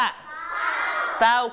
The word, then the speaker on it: เศร้า: เศร้าคู่เขาหนูมีอาการเศร้าจังเลยค่ะไม่ต้องไปออกรอเรือนะลูกออกแค่ตัวหน้าคือตัวสอสารานะเศร้าเศร้าจ้าคำที่ 3 อ่านว่า